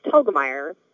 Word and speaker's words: Telgemeier: It's Telgemeier, which rhymes with Helga, like the name Helga, and then Meyer, as in Oscar Meyer Wiener.